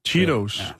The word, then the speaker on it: Cheetos: der må du hjælpe mig med at udtale det, Cheritos. Det er dem, som laver cheesy, det hedder Cheetos.